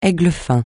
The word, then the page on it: aiglefin: français